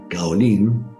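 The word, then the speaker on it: caolín: El blanco kaolín representa la... – ¿Blanco cómo? ¿Blanco? – Kaolín. Kaolín. Es con C. C se pronuncia kaolín.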